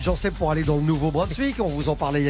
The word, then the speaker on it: Nouveau-Brunswick: C'est bien comme ça qu'on prononce, hein, Nouveau-Brunswick.